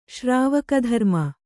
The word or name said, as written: ಶ್ರಾವಕಧರ್ಮ